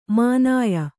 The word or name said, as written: ಮಾನಾಯ